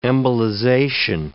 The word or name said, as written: embolization